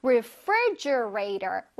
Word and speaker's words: refrigerator: fri, 저기 빨간색으로 표시된 부분, free 부분을 또렷하게, 뚜렷하게 발음해줘야 돼. refrigerator, refrigerator. 근데, 이것도 사실 너무 길어서, 이 refrigerator를 줄여서 fridge라고도 많이 합니다.